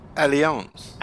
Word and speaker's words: Allianz: it's pronounced Allianz.